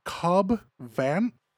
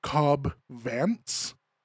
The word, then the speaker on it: Vanth: There was a character in the show named Cobb Vanth. Vanth. And I kept calling him last episode Cobb Vance, like Bob Vance Refrigeration.